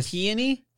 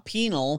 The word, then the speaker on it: Peony: I think his name is like Penol. It's P-E-O-N-Y. I don't know how to pronounce that. I want to Peony? – There we go. That's his name. – Not Penal, Peony.